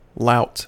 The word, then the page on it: lout: American English